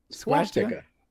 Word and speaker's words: swastika: Swastika. It's swast. There's no ed. There's no H.